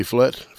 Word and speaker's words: Flett: Buddy Flett. Flett is spelled F-L-E-T-T in case my enunciation is insufficient.